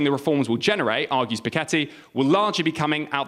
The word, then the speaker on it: Piketty: The French economist Thomas Piketty, a pronunciation that is correct and yet I still disagree with, has argued the pension reforms are unfair.